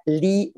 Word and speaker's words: Lea: hay un libro muy bonito de la doctora Lee Waters, se escribe Lea Waters como agua, como hago en inglés, que se llama Crianza centrada en fortalezas.